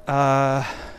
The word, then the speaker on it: Naranjo: Naranjo, I like to put the emphasis on the ho, although it's N-A-R-A-N-J-O,